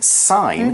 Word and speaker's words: sign: Now here we've used the word sign, S-I-G-N, sign. Mm-hmm. Be careful with that spelling and how you say it. It's a silent G, it's not sign, it's sign.